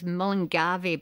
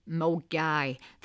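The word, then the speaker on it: Milngavie: Now, some people, if not from Glasgow, they pronounce it as Mullingavy, but Glaswegians, they know it as Mulgay.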